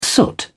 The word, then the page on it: soot: British English